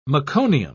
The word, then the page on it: meconium: British English